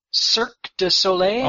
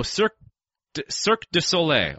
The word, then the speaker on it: Cirque du Soleil: Is that how I pronounce it? – Cirque du Soleil? – Oh, Cirque du Soleil.